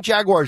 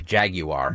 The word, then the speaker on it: Jaguar: How do you say that, by the way? Because I think Jaguar is incorrect. I think the way they say it, isn't it like... – Jaguar. – That's how, I think the British say it that way.